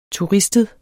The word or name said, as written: turistet